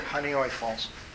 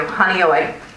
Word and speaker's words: Honeoye: It's spelled H-O-N-E-O-Y-E, Falls, Lima Central School District. – Honeyoy Falls. – Thank you, Honeyoy, thank you.